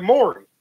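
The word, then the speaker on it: Maury: We tell people that our podcast is like Mari, but on the radio. – It's not, though, is it? – Yeah, it's like Mori.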